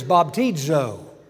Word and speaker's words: baptizo: The word is baptizo. It's spelled thusly, B-A-P-T-I-Z-O.